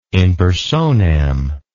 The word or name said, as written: in personam